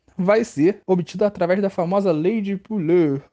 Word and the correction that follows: Pouillet: ou não sei falar o nome desse cara em francês não, Pouillet, que se lê, Pouillet, que se escreve no caso.